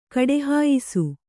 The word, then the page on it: ಕಡೆಹಾಯಿಸು: kaḍehāyisu; Kannada